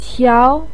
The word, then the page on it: qiao: 2